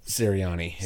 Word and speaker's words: Sirianni: Nick Sirianni? – Sirianni is correct, yes.